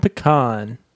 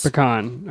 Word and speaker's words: pecan: Pecan. – Okay. Pecan. – Oh, what would you say? Southern pecan. – No, I think in the north, it's pecan. I say pecan now, but I've definitely heard pecan.